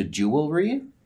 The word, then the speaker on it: jewelry: There's in one of Oscar Wilde's plays, somebody takes beef with the fact that somebody says jewelry as opposed to jewelry. It's supposed to be three syllables, not two.